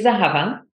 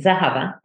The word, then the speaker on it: Zehava: Hi, my name is Zahava, and that's how I pronounce it, Zahava. It's actually pronounced like it's spelled, Z-E-H-A-V-A.